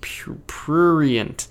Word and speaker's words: Prurient: We're talking about purient. Or sorry, prurient. I can't ever say that right.